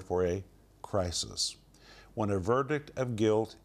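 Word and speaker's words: crisis (κρίσις): The word judgment in Greek is the word pronounced crisis.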